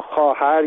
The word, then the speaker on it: خواهر: به عنوان مثال ما خواهر یا خواستن رو با واو می نویسیم، در حالی که این صدا آ هست. علتش اینه که در گذشته خواهر یا خواستن تلفظ می شده.